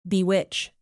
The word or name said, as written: bewitch